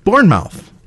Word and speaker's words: Bournemouth: Bournemouth. That's how you say it.